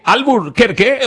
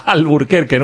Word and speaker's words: Albuquerque: Llega desde Albuquerque, hoy lo he dicho bien, ¿eh? A ver. Albuquerque,